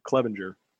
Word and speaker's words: Clevinger: Clevinger.